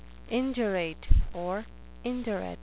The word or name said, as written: indurate